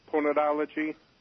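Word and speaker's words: Ponerology: Do you know about the book by a Polish psychiatrist, Political Ponadology? – No.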